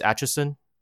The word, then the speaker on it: Acheson: Merchant was sent by, is his name pronounced Acheson? – Yes.